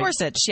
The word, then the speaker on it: Gorsuch: Is that how he pronounces his name? It's Gorsuch, right? – Gorsuch, yes. Yeah.